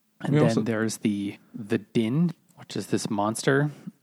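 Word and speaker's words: Dynne: But it's spelt weird. Like the Dynan, and it's D-Y-N-N-E, but Din, D-I-N is the noise, like a bunch of, this is the word for a bunch of loud, obnoxious sounds.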